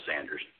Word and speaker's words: Sanders: Is it Saunders or Sanders? I should have asked you that. – It's Sanders. – Oh, Sanders. I made it Saunders, so good to know.